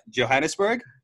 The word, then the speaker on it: Johannesburg: Johannesburg? – No, you pronounced that very correctly.